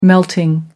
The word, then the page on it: melting: American English